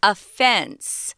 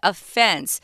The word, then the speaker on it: offense: Now, when you're talking about this word offense and you're talking about someone's been accused of that they're guilty of doing, um, we use the pronunciation offense right? Listen to that offense. So the Zhongyin, I'm thinking Zhongyin, the strong syllable is on the second syllable, offense.